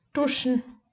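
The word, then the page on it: duschen: Bavarian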